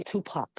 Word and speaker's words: Tupac: Yeah, Tupac.